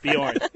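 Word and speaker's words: Björn: The J is silent. – I get yelled at for it. Bjorn.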